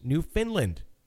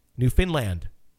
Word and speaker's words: Newfoundland: It's pronounced Newfoundland, with the emphasis on land. Newfoundland.